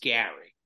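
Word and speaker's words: Gerry: It's spelled Jerry, pronounced Gary.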